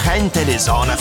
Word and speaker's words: Gente de Zona: Gente de zona. In realtà la pronuncia è spagnola perché sono cubani. Gente de zona,